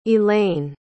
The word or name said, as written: elain